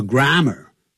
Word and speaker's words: grammar: 我痛恨，本来是 hate, 加了个 D, 就变成过去时，讲了我当时，我痛恨英语，或是英文的语法 ,grammar, 不要念成 grammar, 叫 grammar.